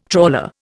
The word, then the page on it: trawler: Indian